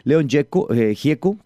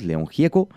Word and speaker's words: Gieco: El señor es argentino también, León Gieco, como lo pronuncian ellos, León Gieco,